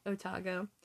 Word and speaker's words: Otago: Otago.